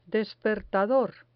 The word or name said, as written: despertador